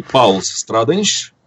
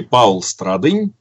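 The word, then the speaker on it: Pauls Stradiņš: Оно произносится по-разному, имя и фамилия, в зависимости от того, как на латышский манер или на русский. Поэтому и Паулс Страденьш, и Паул Страдынь, и Павел Иванович Страдынь.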